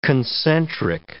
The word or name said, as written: concentric